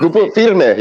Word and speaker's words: Grupo Firme: Grupo Firme. – Grupo Firme.